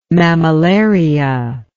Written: mammillaria

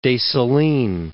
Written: Dessalines

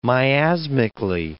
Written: miasmically

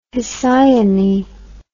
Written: Hesione

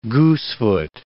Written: goosefoot

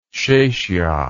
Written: chechia

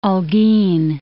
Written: Holguin

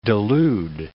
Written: delude

